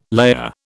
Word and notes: layer: British English

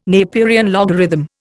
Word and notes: Napierian logarithm: Indian

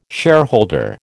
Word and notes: shareholder: American English

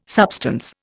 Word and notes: substance: Indian

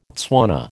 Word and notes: Tswana: American English

